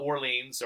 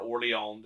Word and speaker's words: Orléans: And as much as I love Euro games, you know, I just played a wonderful game of Orleans or Orléans, depending on how you want to pronounce it, like 4 days ago with my gaming group.